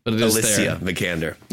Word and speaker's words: Alicia Vikander: So it's technically Alicia Vikander.